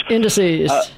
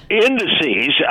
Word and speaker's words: indices: who doesn't mispronounce the plural of index. He properly says... – Indices. – Indices.